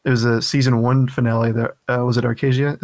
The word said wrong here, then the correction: Acrasia: is that how you say it? – Acrasia.